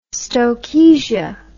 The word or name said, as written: stokesia